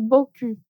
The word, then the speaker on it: beaucoup: O U não tem som de I, como em menu, e o U não tem som de U, como em merci beaucoup.